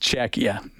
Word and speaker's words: Czechia: So, IMAC, yeah, Czechia. Yeah, tell Bob it's pronounced Czechia.